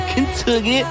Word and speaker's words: Kintsugi: Elisa con Kintsugi. – Avete notato la pronuncia proprio stretta nipponica, Kintsugi.